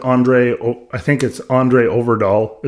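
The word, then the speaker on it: André Øvredal: Doing research for this, I did not realize that Andre Overdahl is how you pronounce it, directed this movie.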